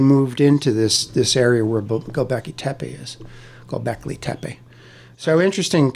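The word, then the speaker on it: Göbekli Tepe: No, doing research for Gobekli Tepe, Walt's going to smack me if I don't get the pronunciation right. – It's a difficult pronunciation, but Gobekli Tepe.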